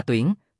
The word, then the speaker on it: tuyển: Ứng phanh gì ơi, chữ này đọc là tuyển, tuyển ngạ An Cát Lạc rất khó khăn nói, ứng tuyển lần này đúng rồi. Các nữ nhân hầu gia trang đồng thanh cười, thành âm sai là thành chữ khác đấy.